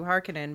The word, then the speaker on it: Harkonnen: Yeah, I mean, it's also like they changed from Harkonnen, from Harkonnen to Harkonnen because they're like, oh wait, let's actually pronounce it more like the Finnish name.